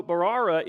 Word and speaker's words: Mbarara: You spell it with an M at the beginning, but M-B-A-R-A-R-A, but the M is silent. Anyway, if you're looking it up. So Barara is like one of the youngest cities in the world.